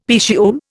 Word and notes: petiole: Indian

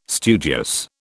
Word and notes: studious: British English

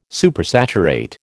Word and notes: Supersaturate: American English

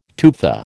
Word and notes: tufa: American English

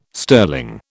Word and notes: sterling: British English

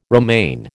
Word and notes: romaine: American English

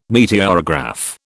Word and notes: meteorograph: British English

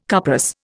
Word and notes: cuprous: Indian

